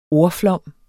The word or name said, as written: ordflom